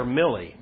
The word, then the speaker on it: Milley: Is his name pronounced Miley or Millie? Millie Vanilli. That's how you remember.